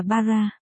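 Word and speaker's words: bara (薔薇): Nguyệt quý, tường vi, trang quay, thực ra cái cụm này mới được dùng để chỉ hoa hồng trong tiếng Nhật Ý, đọc là bara.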